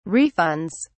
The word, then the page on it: refunds: British English